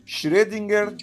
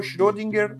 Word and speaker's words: Schrödinger: Vamos chamar, Ramon, pro nosso bem aqui, eu sei que esse O com E é um som difícil pra gente brasileiro pronunciar. Vamos chamar de O Schrödinger ou Schrödinger, o que você achar mais confortável.